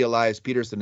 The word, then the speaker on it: Pettersson: He wants to be Elias Peterson instead of Elias Pettersson.